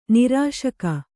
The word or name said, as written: ನಿರಾಶಕ